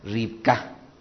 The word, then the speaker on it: Rivka: Todas estas variantes son las que podríamos encontrar relacionados con el nombre que en estricto sentido al español se debería de pronunciar Ribka.